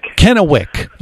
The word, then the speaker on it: Kennewick: Am I saying it right? Kennewick, right? – Kennewick. – Okay, Kennewick. It's an accent thing.